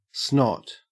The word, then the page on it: snot: Australian English